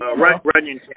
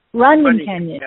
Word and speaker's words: Runyon Canyon: Is it the Rawson Canyon? – Runyon Canyon. – It's another really popular hike. – Yeah, it's pronounced Runyon Canyon.